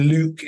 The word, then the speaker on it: Luc: Okay, because my name, Luc, is spelled the French way, L-U-C, and teachers at high school in the UK growing up would see a list of names and think it was Lucy with the Y missing, all right, which obviously was always amusing.